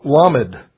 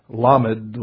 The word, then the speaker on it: Lamed: Well, Lamed, that's how you would pronounce the letter. Lamed, with a stress on the first syllable.